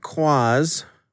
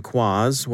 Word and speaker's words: QQAAZZ: Department of Justice announced yesterday that it had indicted 14 members of Quaz. We're just going to go with that pronunciation since it's spelled Q-Q-A-A-Z-Z, so your guess is as good as mine.